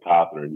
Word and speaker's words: Cothrine: You know, I've gotten all versions of Cothrine, Cothrine, Cothrine. Now you got it, Cothrine, you got it.